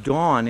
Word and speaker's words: Dan: Dan is pronounced Dawn in Hebrew, D-A-W-N, Dawn.